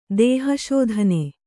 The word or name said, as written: ದೇಹಶೋಧನೆ